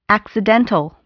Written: accidental

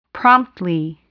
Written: promptly

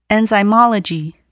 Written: enzymology

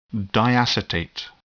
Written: diacetate

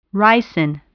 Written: ricin

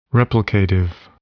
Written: replicative

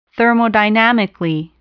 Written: thermodynamically

